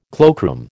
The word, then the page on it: cloakroom: American English